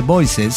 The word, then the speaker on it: Boise: Nací en Bois, nunca entendí cómo se pronuncia eso. Si alguien de Idaho me puede explicar cómo se pronuncia, porque es francés, Boise. – Sí. – Se pronuncia Boise. Sí, se pronuncia así.